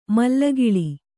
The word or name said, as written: ಮಲ್ಲಗಳ